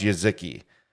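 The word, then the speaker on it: Jeżyki: J-E-Z-Y-K-I. – Pronounced Jezki.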